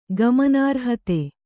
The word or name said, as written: ಗಮನಾರ್ಹತೆ